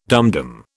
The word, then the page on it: dumdum: British English